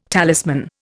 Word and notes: talisman: Indian

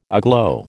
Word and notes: aglow: American English

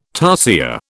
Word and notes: tarsier: British English

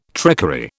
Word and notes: trickery: British English

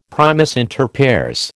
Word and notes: primus inter pares: American English

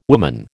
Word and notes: woman: American English

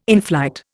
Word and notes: inflight: Indian

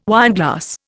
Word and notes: wineglass: Indian

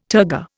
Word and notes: turgor: Indian

